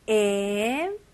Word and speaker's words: improve: Improve. Improve. 네, 마지막에 V 발음 잘 해주시고요.